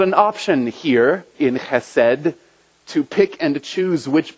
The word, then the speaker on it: chesed: It's based on a Hebrew word that's pronounced chesed, and it's used dozens and dozens of times in the Old Testament.